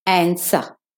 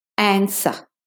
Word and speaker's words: answer: So the first word is two, T-W-O. That one you probably already know. But often people say answer because they see the W there when the W is silent in the word answer. Answer. If you're doing the American, answer. Yeah, but the W is still silent. The next word is sword. They fought with a sword. S-O-R-D.